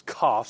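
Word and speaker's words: cough (קוף): This is an easy one to pronounce. If you plug it into your phone will just cough at you, okay, because that's how the word is pronounced. It's cough. Sometimes you'll see it with a K, most often with a Q, but we're looking at that this morning.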